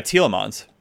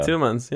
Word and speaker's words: Tielemans: Oh, you got Tielemans. – Yeah, there you go. – Tielemans, yeah.